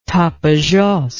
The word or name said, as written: Tapajos